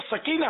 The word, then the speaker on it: سکینہ: یہ ہمارے یہاں معروف تو سکینہ ہے، ویسے سکینہ اصل تلفظ یہی ہے، یہ سکینہ اور چیز ہے.